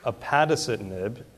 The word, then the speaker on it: upadacitinib: This agent, which is upadacitinib, and it took me a little while to pronounce that correctly, also called ABT-494.